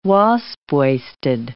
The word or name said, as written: wasp-waisted